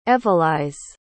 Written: evalyse

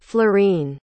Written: florene

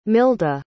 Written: milda

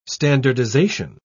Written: standardization

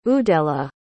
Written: udela

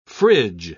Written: fridge

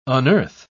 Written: unearth

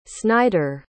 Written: snider

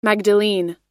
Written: magdalene

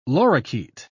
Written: lorikeet

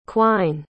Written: quine

